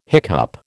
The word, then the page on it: hiccup: American English